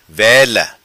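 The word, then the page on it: Vela: patua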